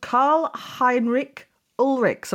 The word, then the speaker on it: Karl Heinrich Ulrichs: We are talking about Karl Heinrich Ulrichs. Am I saying that correctly? – You are, absolutely.